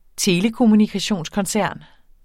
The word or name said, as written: telekommunikationskoncern